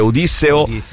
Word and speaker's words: Odisseo: Invece per quanto riguarda Nolan, c'è un articolo sul Post che spiega che innanzitutto bisognerebbe dire Odisseo e non Ulisse, cioè bisognerebbe dire Odisseo. Con l'accento sulla prima I, anche se è più diffusa la pronuncia greca Odisseo.